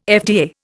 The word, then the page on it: FDA: Indian